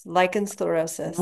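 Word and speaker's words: lichen sclerosus: and I don't even know if I'm going to say it right, but I'm going to try, lichen sclerosis. – Yes, lichen sclerosis.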